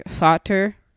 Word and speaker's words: Vater: It's like Vater. – Okay. Damn. – Like it's a V.